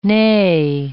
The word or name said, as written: neigh